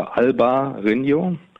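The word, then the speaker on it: Albariño: man also diese beiden Silben auseinanderzieht ähm, und äh, spricht es auf Spanisch aus, also Alba Rino,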